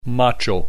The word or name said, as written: macho